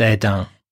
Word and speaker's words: Hesdin: First stop was a lovely small town by the name of Hesdin, pronounced Edin, where we had some delicious ships.